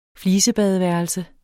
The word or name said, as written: flisebadeværelse